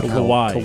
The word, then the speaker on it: Kawhi: How do you pronounce it? Is it Kawhi Leonard? – Oh, Kawhi.